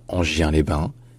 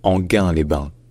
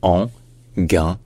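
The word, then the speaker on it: Enghien-les-Bains: Parce que ça ne se dit pas Angien-les-Bains, ou Anguien-les-Bains, ou même Angin-les-Bains, mais Anguin-les-Bains. Anguin-les-Bains.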